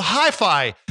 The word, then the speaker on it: HyFi: It's called Hi-Fi. It's spelled H-Y-F-I.